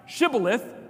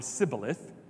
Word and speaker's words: shibboleth: their accent wouldn't allow them to say the sh sound. So that was a test of where they truly were from. And everyone that could not pronounce shibboleth, their head was cut off, killed.